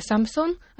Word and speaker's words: Samsung: Samsung, как мы уже сказали, в транскрипции правильно произносится Samsung, означает «три звезды», и связь такого названия, скорее всего, возможна с тремя сыновьями основателей компании.